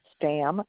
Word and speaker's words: Stamm: Brooke Stamm, STAMM.